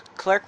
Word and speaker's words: Klerk: For the first thing, I'll say my name's Klerk Prestion. Um, it's an interesting name. I spell it K L E R K, Klerk, and then Prestion.